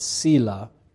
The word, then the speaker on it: Selah: I've pronounced that Selah. Some have pronounced it Selah. There's different ways it's pronounced.